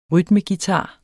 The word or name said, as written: rytmeguitar